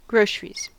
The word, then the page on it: groceries: American English